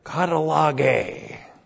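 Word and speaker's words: katallagē: Specifically, that ministry is called here katalage. K-A-T-A-L-L-A-G, long E.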